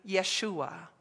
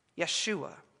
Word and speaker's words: Yeshua: Jesus is the English pronunciation of the Greek Jesus, which is the same as the Hebrew name Yeshua. Yeshua, a shortened version of Yehoshua, which translates to Joshua in English.